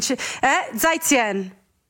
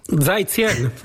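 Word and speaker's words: Zai Zien (再见): che Zai Zien. – Leonardo, hai capito? – Zai Zien.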